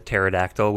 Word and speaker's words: pterodactyl: I like that his name is Petrie because he's a pterodactyl with the silent P. So P-T, like, that works. – I never thought of that. That's kind of nice. – P. Yeah, it begins with P as in pterodactyl.